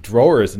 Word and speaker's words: drawers: It's something weird about the pronunciation of that, drawers. I think it's, yeah, almost like doors, but drawers. So couldn't you spell it D-R-O-O-R-S, drawers?